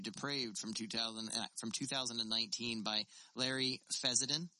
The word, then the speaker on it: Fessenden: I want to call him Fassbender no matter what, and it's not...